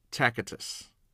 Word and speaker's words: Tacitus: His name was Tacitus. I'm not sure if I'm saying that correctly, but it's T-A-C-I-T-U-S, Tacitus, I believe.